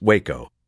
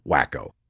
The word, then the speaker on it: Wacko: The guy who wasn't feeding collected the finished products and stacked them for our boss, Leroy Wacko, whose name was actually pronounced with the long A, like the city in Texas, Waco, but of course we called him Wacko.